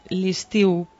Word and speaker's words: L'Istiu: Listiu con I, que es tal como lo pronunciamos aquí.